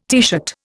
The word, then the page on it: teeshirt: Indian